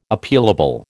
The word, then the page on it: Appealable: American English